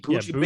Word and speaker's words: Buju: Buju? – That's right. Buju Banton's on it.